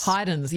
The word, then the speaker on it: Haydn: But we say Haydn, hide and seek. So Michael Haydn, 5 years younger to his much more famous brother, Joseph Haydn.